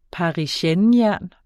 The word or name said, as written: parisiennejern